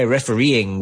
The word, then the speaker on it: refereeing: Yeah, refereeing, we would say. We'd put the emphasis on the E's and refereeing.